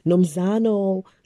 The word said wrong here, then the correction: Nomzamo: Nomzamo say that right, Mbatha.